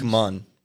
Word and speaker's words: CMON: ¿cómo es que se pronuncia esta empresa? Simon, Common Games. Come on. C-M-O-N.